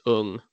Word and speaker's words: Ng: But Perry, if he's of New Zealand origin, which I believe he might be, it's Ung.